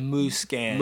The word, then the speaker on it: Musquin: Tell me again how you say his name properly. Mooskin. – Mooskin. Mooskin.